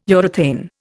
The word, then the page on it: urethane: Indian